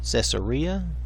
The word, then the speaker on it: Caesarea: And the Caesarea, oh, it's Caesarea, I'm sorry, I keep wanting to say that wrong, I do apologize, sent to me from Caesarea.